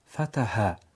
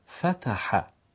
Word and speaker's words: فتح: اگر بگیم فتح خطاست. فتح.